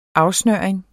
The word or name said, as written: afsnøring